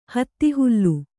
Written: ಹತ್ತಿಹುಲ್ಲು

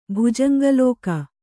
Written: ಭುಜಂಗಲೋಕ

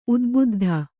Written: ಉದ್ಬುದ್ಧ

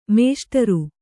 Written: ಮೇಷ್ಟರು

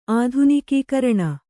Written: ಆಧುನಿಕೀಕರಣ

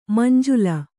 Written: ಮಂಜುಲ

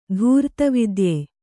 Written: ಧೂರ್ತವಿದ್ಯೆ

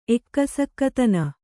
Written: ಎಕ್ಕಸಕ್ಕತನ

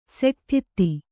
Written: ಸೆಟ್ಠಿತ್ತಿ

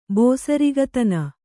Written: ಬೋಸರಿಗತನ

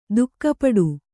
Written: ದುಃಖಪಡು

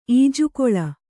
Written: ಈಜುಕೊಳ

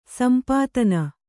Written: ಸೊಂಪುತನ

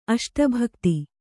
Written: ಅಷ್ಟಭಕ್ತಿ

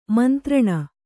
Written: ಮಂತ್ರಣ

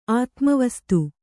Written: ಆತ್ಮವಸ್ತು

